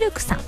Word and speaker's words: CILQ: そして南青山にフレンチレストランシルクさん、 CILQ と書いてシルクさん、